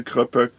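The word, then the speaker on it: Kroepoek: Bier und Limo war dabei und dann noch so diese Kröpöck Krabbenchips. – Ja, ich glaube, das ist holländisch und da wird OE als U ausgesprochen, deswegen habe ich Kröpöck.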